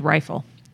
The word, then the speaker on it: Riefel: I think it's Riefel. – Okay, Riefel. I'm going to say Riefel. – Because it rhymes with the Eiffel Tower.